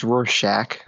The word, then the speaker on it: Rorschach: It's Rorschach. – Rorschach. – Like the tech.